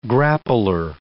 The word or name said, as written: grappler